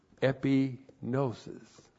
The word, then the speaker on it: epignosis: Now, that word, knowledge of His will, is the word epignosis. E-P-I-G-N, long O-S-I-S.